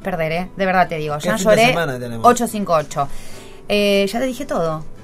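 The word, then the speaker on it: Jean Jaurès: Y después, si vos querés, a las 8:30, en la Carpintería, que es la calle, se escribe Jean Jaures. Pero se dice Jean Lloré. Jean Lloré 858, que es Jean Jaures, Capital Federal.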